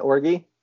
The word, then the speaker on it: Origi: Was it Origi? – Yeah.